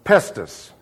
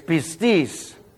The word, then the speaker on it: pistis: Now, you know how they pronounce this one. Pistis. No, it's pistis.